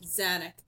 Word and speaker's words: Zanuck: Zanuck? I don't know how that's pronounced. – Zanuck.